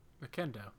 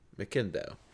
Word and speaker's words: McIndoe: McIndoe. – McIndoe. Anyway, there's McIndoe. – McIndoe. – McIndoe.